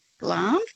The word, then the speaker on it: Glamis: It's called Glam's. It's spelled G-L-A-M-I-S, Glam's, but it's pronounced Glam's thistle.